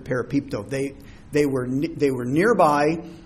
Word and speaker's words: parapipto: It's P-A-R-A-P-I-P-T-O, parapipto. I'm obviously pronouncing that wrong. I'm not Greek. P-A-R-A-P-I-P-T-O, parapipto.